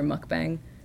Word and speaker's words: mukbang: Like if you Google Jasper mukbang, or how do you say it, mukbang?